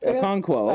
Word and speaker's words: Okonkwo: Chigizo Akinkwo. I believe I'm trying to say his name right. – Chigozium Okonkwo? – Okonkwo. – Out of Maryland? – There we go.